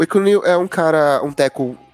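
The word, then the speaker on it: Ekwonu: Eu vou dizer que eu gosto um pouco mais do Ikin Ekwunil, é, a pronúncia do nome dele é Ekwunil, é, segundo ele mesmo falou,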